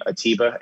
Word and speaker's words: Atiba: Atiba, A-T-I-B as a boy, A, and Madyun, M-A-D-Y-U-N.